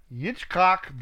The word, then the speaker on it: Yitzchak: Verse 40, and Yitzchak, the son of Abraham, that's Isaac, I'm pronouncing it in the Hebrew pronunciation, okay, was growing up in those days.